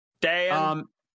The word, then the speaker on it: Deion: We've got, how you pronounce this? Deion Henley, the linebacker. – Deion.